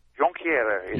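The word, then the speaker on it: Jonquière: Jonquiere is the right way. Jonquiere. – The French way.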